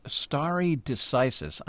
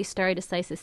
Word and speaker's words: stare decisis: I'm not even sure I'm pronouncing this correctly, not being a lawyer or legal scholar. I'm asking you to explain this for us. – You're pronouncing it beautifully, and narrowly stare decisis simply means you don't go around upsetting prior court rulings willy-nilly.